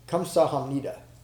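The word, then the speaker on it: kamsahamnida (감사합니다): So, the Korean word for thank you is, it's spelled with a G, but it's pronounced, well, it's kamsahamnida. It's spelled with a G, but the first letter is really a hard K and a soft G kind of put together.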